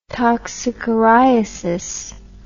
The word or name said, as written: toxocariasis